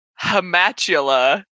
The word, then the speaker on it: Hamatula: I forgot to read my note that I wrote when we were talking about devils, which was my favorite devil name is Hamatula, because you can just pronounce it like ham spatula.